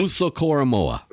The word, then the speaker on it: Owusu-Koramoah: Jeremiah Owusu-Koramoah. – Yeah, Jeremiah. – Owusu-Koramoah. – J-O-K.